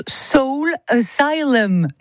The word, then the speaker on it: Soul Asylum: Donc oui, je disais tout à l'heure en intro d'émission, nous on disait Soul Asylum, mais donc le nom du groupe se prononce Soul Asylum normalement.